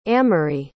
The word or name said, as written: amery